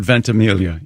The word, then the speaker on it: Ventimiglia: Is it Ventimiglia? – Does he go with the soft G, or does he do the Mike Birbiglia thing? – I have heard the soft G. – I've heard Ventimiglia. Yes.